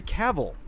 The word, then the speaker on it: Cavill: It's Henry Cavill, like travel. I've been saying that wrong my whole life.